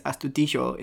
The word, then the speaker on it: Astudillo: Which is, the main thing is that, that I think that there are some dialects of Spanish which will, like, give, like, a soft J to a double L. – Mm-hmm. – So he is Astudillo in my head.